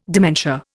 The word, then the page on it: dementia: Indian